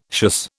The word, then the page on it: schuss: British English